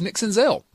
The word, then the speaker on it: Senzel: Nick Senzel, who it seems like is one of our lead stories every single week because he's Nick Senzel. It rhymes with Denzel.